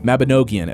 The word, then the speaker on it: Mabinogion: Mabinogion. I've been saying it incorrectly throughout the episode, but I'm not going to go back and re-record it because we've got too much exciting shit to cover.